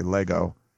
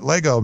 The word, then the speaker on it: Lagow: Richard Lego, I think you said Lego is what they were saying on the broadcast. They spell it L-A-G-O-W, but I think they say Lego.